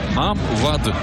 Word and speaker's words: Maam Wade: De nieuwe nummer 10, Maam Wade. Zo spreek je het blijkbaar uit.